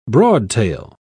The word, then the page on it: broadtail: British English